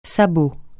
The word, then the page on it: sabot: francais